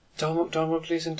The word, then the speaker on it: Domhnall Gleeson: Domhnall Gleeson, Domhnall Gleeson.